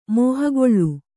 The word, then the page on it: ಮೋಹಗೊಳ್ಳು: mōhagoḷḷu; Kannada